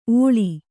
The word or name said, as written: ಓಳಿ